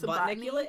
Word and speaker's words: Botanicula: but Botanicula how do you pronounce it? Isn't it Botnicula? – Bot- it's Botnicula.